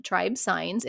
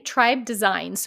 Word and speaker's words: Tribe Signs: On a side note, I don't know what it is, but every time I look at the name Tribe Signs, it's spelled T-R-I-B-E-S-I-G-N-S. I keep saying Tribe Designs, so if I accidentally say Tribe Designs, I don't know what it is.